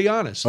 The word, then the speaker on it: Giannis: A nice soft Giannis. – You're nailing it. – Yeah, sure. But I don't want any Greeks calling it going, you know, we don't pronounce the S.